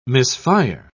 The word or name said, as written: misfire